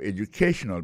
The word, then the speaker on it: educational: We'll be talking about your educational background. Alors, accent tonique là, koto pesé publis là, li tombe sous troisième syllabe là.